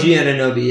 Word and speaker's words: Anunoby: Anunoby? – OG Anunoby, yes.